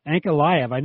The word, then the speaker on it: Ankalaev: Ankalaev.